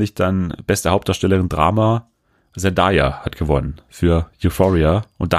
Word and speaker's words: Zendaya: Fun Fact, ne, ihr Name wird eigentlich, der wird Zendaya ausgesprochen. Ich dachte früher auch immer, man sagt Zendaya und dann habe ich mal so einen Livestream mit ihr geguckt und dann hat sie jemand gefragt, wie ihr Name ausgesprochen wird und sie dann so, ja, Zendaya, weil so kreativ war meine Mutter nicht, dass ich jetzt Zendaya heiße.